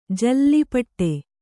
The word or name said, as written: ಜಲ್ಲಿಪಟ್ಟೆ